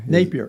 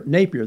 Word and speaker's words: Napier: Did I say his name right? Napier? I don't know how he's going to do it. But there Napier. – Napier.